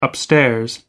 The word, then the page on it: upstairs: American English